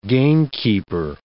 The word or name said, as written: gamekeeper